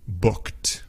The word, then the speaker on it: booked: Booked. Booked. Si te das cuenta, no es booked. No, es booked. Booked. Es una sola sílaba. Suena como una sola sílaba en inglés. Booked. Of course, it's spelled with the E-D, right? B-O-O-K-E-D.